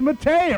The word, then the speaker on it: Maté: And Rob is going to introduce us to the world-famous folk singer, Maurice Maté. It rhymes with paté, the Cajun way of saying partay.